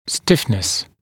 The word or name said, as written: stiffness